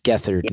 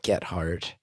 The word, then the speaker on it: Gethard: His name is Chris, and it's pronounced Gethard, not Gethard.